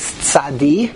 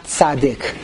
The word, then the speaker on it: Tzadi: The way I pronounce the letter is Tzadi and not Tzadik. When I was in Cheder, they taught me Tzadik. I did not know that it was really called Tzadi until much later in life.